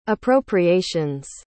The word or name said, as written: appropriations